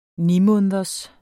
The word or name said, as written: nimåneders